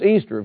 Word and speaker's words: Ishtar: That name is found by Layard on the Assyrian monuments is Ishtar, I-S-H-T-A-R, but pronounced Easter, of course.